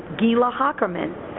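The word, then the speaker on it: Geila Hocherman: Her name is spelled G-E-I-L-A Hockerman, H-O-C-H-E-R-M-A-N, Gila Hockerman.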